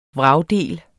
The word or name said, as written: vragdel